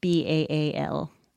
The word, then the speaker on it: Baal: Which I think should be pronounced Baal. I think that sounds much more sinister. – I think that does sound more sinister, and I think that's probably closer to the original pronunciation.